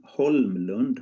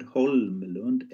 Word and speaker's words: Holmlund: Johan Holmlund. Holmlund. H O L M L U N D. Holmlund. Johan Holmlund. Yeah. – Right. So when I sort of say your name, you'll probably cringe and think, that's that's an English person. – It's not Homeland.